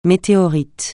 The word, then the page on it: météorite: francais